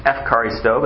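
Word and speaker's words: efcharisto (ευχαριστώ): The Greek underline that is eucharisto. In fact, in modern Greek today, it's pronounced efcharisto, but that's how they say thank you.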